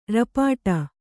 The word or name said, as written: ರಪಾಟಿ